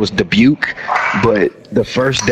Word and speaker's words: Dubuque: His name is Dubuque. Dubuque. Dubuque, Iowa. the um the his name is spelled weird. It's hard to pronounce for idiot. And apparently one of our drill stars an idiot. They called him Dubacue.